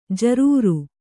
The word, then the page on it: ಜರೂರಿ: jarūri